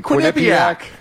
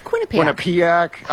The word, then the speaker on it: Quinnipiac: Or the one that starts with a Q that I can never pronounce accurately. – Quinnipiac. – Quinnipiac. I always get corrected on that one because I always read it. I don't hear it pronounced.